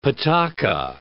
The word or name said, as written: pataca